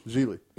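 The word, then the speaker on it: Gigli: Isn't it like Giggly or Jiggly or some shit? – Geely. – Geely. – Is it Geely? Yeah. – Yes.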